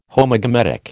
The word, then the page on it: Homogametic: American English